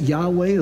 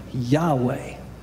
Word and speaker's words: Yahweh: We pronounce it Yahweh as sort of our best guess as to how that's pronounced.